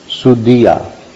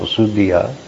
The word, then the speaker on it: sudhi: Sudhiya. – Sudhi. This is in the plural. Sudhi. D means intelligence. D-H, long I, D. So sudhi. Those who, and this is plural, so sudhiya.